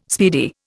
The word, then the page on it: speedy: Indian